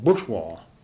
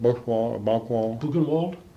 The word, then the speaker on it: Buchenwald: there was three jeep loads of us soldiers, and we raced across into Germany, and we went to this concentration camp called Buchenwald. I'm not pronouncing it right. Buchenwald.